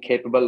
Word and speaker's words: CAPABL: So that's how we're pronouncing capable, like we're missing an E.